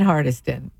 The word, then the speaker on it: Hardyston: Sandyston and Hardyston. But they pronounce the Y just like it's a short I. How about Sakasana, who people who live there call it Sakasana, like a sauna, S-A-U-N-A, and it's Metuchen, clearly Sakasana.